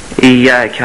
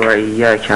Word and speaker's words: ایاک: یه بار دیگه بگید ایاک نعبد و ایاک نستعین. – خب این ایاک در کلمه اول تو این جمله با همون کلمه دوم در این جمله در واقع ایاک نستعین مثل هم باید تلفظ بشن.